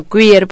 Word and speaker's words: queer: ¿Tú crees que esa teoría queer se pronuncia así? – Sí.